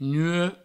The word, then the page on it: nuech: nue